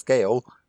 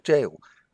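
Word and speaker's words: gaol: I've always pronounced it as Gael, because I'd only just seen it written down, and I had to actually read the pronunciation guide before I was like, oh, this is actually a jail.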